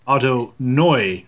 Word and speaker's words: Ottoneu: I'm part German, and the German pronunciation would be Otto Neu, like N-O-I. And I was looking at an old Eno Saris tweet that explains the pronunciation to Dave Cameron, saying the same thing. But in German, Otto Neu would be Otto Neu. So Otto N-O-I, Otto Neu, which sounds really funny off the tongue.